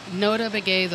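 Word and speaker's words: Notah Begay: Nota Begay the Third Foundation.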